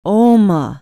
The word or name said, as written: oma